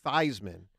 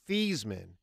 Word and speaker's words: Theismann: Ruben, do you know, speaking of Notre Dame quarterbacks, that Joe Theismann, while at Notre Dame, changed the pronunciation of his name to Theismann from Theismann because he wanted to win, and I'm not making this up, because he wanted to win the Heisman.